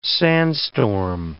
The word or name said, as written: sandstorm